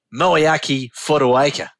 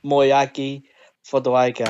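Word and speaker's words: Moeaki Fotuaika: So they keep calling him Moayaki Fotuaika, the prop forward, and it's not correct at all. His name is Tongan, so correct pronunciation. I can't even say the word correct. Anyways, correct pronunciation is Moayaki Fotuaika.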